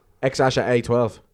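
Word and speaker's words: X Æ A-12: X Ash A 12. – X Ash A 12. – X Ash A 12. – That's how you pronounce his name.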